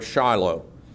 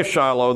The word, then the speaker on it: Shiloh: So when the rabbis read through this and they came to this word Shiloh, they didn't say Shiloh. They said Shelio, which is the pronunciation that means the one who to whom it belongs.